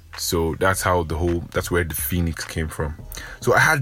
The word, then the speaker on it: Phoenix: I was pronouncing it wrong, and then he told me it's Phoenix, and then kind of explained what it was.